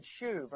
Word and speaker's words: shuv: In Hebrew, with a long U, they'd shuv or turn.